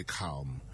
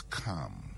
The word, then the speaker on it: calm: People say calm. The word is calm. You are calm, not calm.